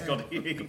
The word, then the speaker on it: Goddy: Goddy rhymes with noddy. – Yes, Goddy.